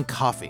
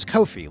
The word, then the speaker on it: Ko-fi: I've been saying coffee because I heard people say coffee, but it's Ko-fi, like like it rhymes with no fee.